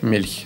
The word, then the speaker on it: Milch: Мягче Milch. Milch.